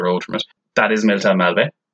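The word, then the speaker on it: Miltown Malbay: Right, so firstly, it's pronounced Milltown Malbay, and it is in County Clare.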